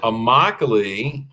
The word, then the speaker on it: Immokalee: So it's Immokalee. That is a word from the Seminole Indian language, and it means my home. You can always tell the name or pronounce the name of an Indian word because every letter is pronounced. Nice. Every letter is articulated. So there's nothing that remains silent.